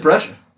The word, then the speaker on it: Brescia: We are actually recording this straight after JSU played their men's home opener, and we absolutely blew out Brescia. – NAIA out of Kentucky. – Is that how you pronounce it? – I think it's Brescia. – It is Brescia.